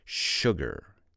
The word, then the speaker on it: sugar: Sugar Remember the word sugar. Remember that's pronounced sugar.